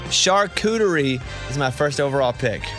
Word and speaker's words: charcuterie: Is that how you say it? Charcuterie? – Yeah, charcuterie.